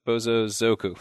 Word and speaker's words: Bosozoku: Oh, well, you know, speaking of things that sound like band names, I should throw into the Bozo Zuko. Man, am I saying that right? Let me just check it real quick. Bozo Zoku.